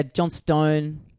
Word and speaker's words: Johnstone: With the Australian pronunciation things, you get a bit lazy. You know, Melbourne turns into Melbourne. – Yeah. – So Johnstone is Johnston.